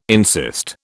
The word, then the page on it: insist: British English